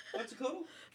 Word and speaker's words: Quetzalcoatl: Kuwitzakil. it's so wrong. Do you know how to say it? I even wrote myself uh, how to say it. uh, uh, what did you say? Quetzalcoatl. Quetzalcoatl. – He's right.